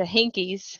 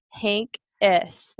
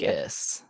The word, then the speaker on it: Henkes: And then I go from Puderek to Hinkies. – Yeah. – Miss Hinkies. It's Henkes. Thank you. Henkes.